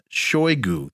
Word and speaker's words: Shoigu: Quote, in the next few hours, we will coordinate all the issues set forth in this document with them, Damascus authorities, Shoigu, that's how you pronounce it, told reporters, showing the memorandum on Idlib settlement signed by him and his Turkish counterpart.